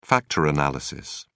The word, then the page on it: factor analysis: British English